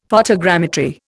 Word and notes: photogrammetry: Indian

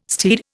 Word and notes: steed: Indian

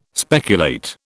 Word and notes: speculate: British English

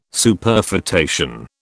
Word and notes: Superfetation: British English

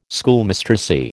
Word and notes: Schoolmistressy: American English

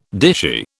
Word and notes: dishy: British English

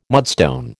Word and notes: mudstone: American English

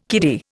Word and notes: kiddie: Indian

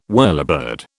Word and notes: whirlybird: British English

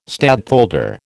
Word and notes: stadtholder: American English

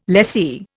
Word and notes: lessee: Indian

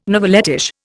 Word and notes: novelettish: Indian

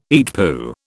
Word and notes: EETPU: British English

